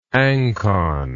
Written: ancon

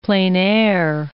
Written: plein air

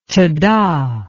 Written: tada

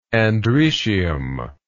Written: androecial

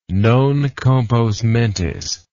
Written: non compos mentis

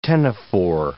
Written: ctenophore

